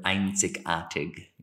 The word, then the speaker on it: einzigartig: einzigartig, you know? – Very singular.